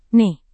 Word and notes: nih: Indonesian

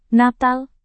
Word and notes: natal: Indonesian

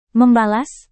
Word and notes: membalas: Indonesian

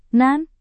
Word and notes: nan: Indonesian

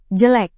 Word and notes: jelek: Indonesian

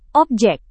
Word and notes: objek: Indonesian

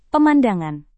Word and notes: pemandangan: Indonesian

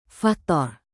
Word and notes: faktor: indonesian

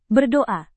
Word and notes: berdoa: Indonesian

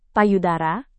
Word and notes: payudara: Indonesian

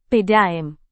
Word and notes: pdam: Indonesian

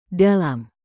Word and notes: dalam: indonesian

